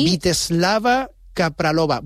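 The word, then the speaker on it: Vítězslava Kaprálová: Vyacheslava Kapralova. Bueno, Lo has dicho bien.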